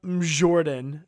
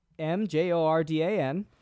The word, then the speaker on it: Mjordan: Mjordan. I'm guessing that's how you say it. – So it's spelled just Mjordan, but I like to reference it as Mjordan. – Majordan.